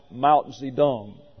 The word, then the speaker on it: Mao Zedong: In January of 49, the communist guerrillas under Mao Zedong, that's spelled this way, MAOTSE-TUNG, pronounced Mao Zedong. Don't ask me how they get a D out of a T, they just do.